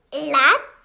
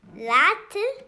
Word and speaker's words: lad: Lad with a D, not a T. Lad. – Lat. – No, not lat. Are you American? – Yes. No. – Say it like an American. – Lad. – Mm-hmm. That's right.